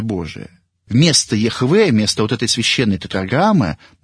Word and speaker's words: Яхве: И там уже стоит не «эхье, эшер, эхье», а всего лишь одно слово, которое филологи определили, что оно произносится как «Яхве».